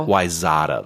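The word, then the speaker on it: Wayzata: They pronounce it Wyzetta, though.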